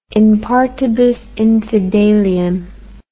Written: in partibus infidelium

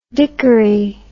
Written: dickory

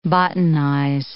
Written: botanize

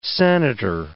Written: senator

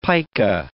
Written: pica

